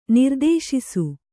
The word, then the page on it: ನಿರ್ದೇಶಿಸು: nirdēśisu